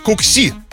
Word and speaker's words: кукси: Кукси. Вот так вот. Ударение на последний «и».